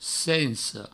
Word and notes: sensa: senso

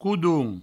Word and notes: codonh: coudoun

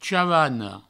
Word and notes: chavana: chavano; provençal

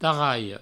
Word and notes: tarralha: provençal; tarraio

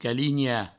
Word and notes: calinhar: provençal; caligna

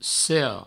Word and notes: sera: sero

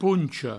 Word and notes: poncha: pouncho

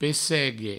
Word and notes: pessegue: Provençal

